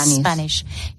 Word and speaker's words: Spanish: Y en inglés tendríamos que decir Spanish. ¿No? – Spanish. – Y luego había gente que también ha pronunciado la S del final como una S española cuando es un sh, ¿no?